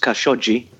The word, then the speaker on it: Khashoggi: Well, Jamal Khashoggi, as I do understand it's pronounced Khashoggi, he was a Saudi Arabian journalist who was resident in the United States, just outside Washington, D.C.